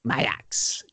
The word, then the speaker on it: Maax: Mayax, do they pronounce it? – Oh, is it took me a while. Okay. I think so, yeah. – It's spelt M-A-A-X. – Yes. – Mayax.